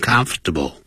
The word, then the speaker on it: comfortable: 特别是 when you aren't comfortable，你感到不舒服的时候，不要忘了这个 comfortable，实际念的时候是念成 comfortable，三个音节，而不是念成四个音节。Comfortable in the water，当然如果你要是念英式的呢，那可能他会念四个音节，他整个就会念成 When you aren't comfortable in the water，那会这样念啊。